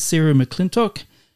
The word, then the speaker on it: Sarah McClintock: Today we've got Sarah McClintock.